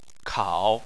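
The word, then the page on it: kao: tone 3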